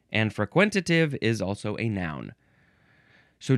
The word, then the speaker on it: frequentative: frequentative. Yep, that's how you say it. Frequentative.